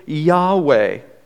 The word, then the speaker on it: Yahweh: But the best pronunciation that we know is probably Yahweh.